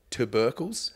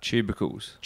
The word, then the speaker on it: tubercles: This paper examines how the addition of tubercles, how do you say that? – Tubercles. – Tubercles. Oh, it is. There's no R.